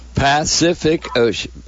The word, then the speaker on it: Pacific: Pacific Ocean. Yeah, P-A-C, that's the S sound. The C sound at the end is a hard C, like the K. And then ocean is like S-H.